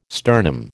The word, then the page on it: sternum: American English